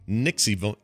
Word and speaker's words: NXIVM: Has been arrested for her involvement in a sex cult called Nixivum. How would you say that? NXIVM. – Oh, yeah. – It's like Nexium. – Well, it looks like Roman numerals except for N.